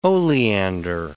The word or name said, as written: oleander